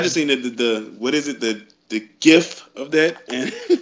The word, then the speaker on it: GIF: It is pronounced GIF.